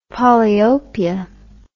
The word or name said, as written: polyopia